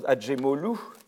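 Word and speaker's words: Acemoglu: Et les auteurs, c'est moglou, ça se prononce Adjemolou, Robinson et Verdier.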